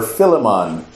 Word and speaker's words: Philemon: Philemon, or Philemon. Philemon, that would be the Jamaican pronunciation.